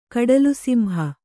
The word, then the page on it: ಕಡಲುಸಿಂಹ: kaḍalusimha